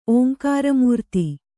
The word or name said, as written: ಓಂಕಾರಮೂರ್ತಿ